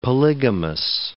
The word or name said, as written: polygamous